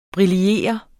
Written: briller

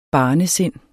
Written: barnesind

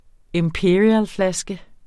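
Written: imperialflaske

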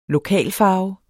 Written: lokalfarve

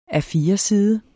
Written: A4-side

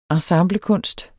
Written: ensemblekunst

